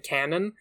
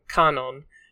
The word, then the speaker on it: Kanon: I almost want to call them Shannon and Canon. But it's, like... It's with the Japanese pronunciation, so it's Shannon and Kanon.